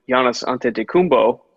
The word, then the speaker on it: Giannis Antetokounmpo: And this is when Giannis Antetokounmpo, I think this year before he got his MVP.